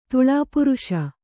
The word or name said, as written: ತುಳಾಪುರುಷ